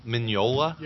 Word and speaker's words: Mignola: Mignola. – Yeah. Yeah, the G is silent.